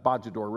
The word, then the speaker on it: Bojador: The Commerce shipwrecked near Cape Bojador, Bojador really is a better way to pronounce it.